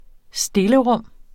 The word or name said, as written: stillerum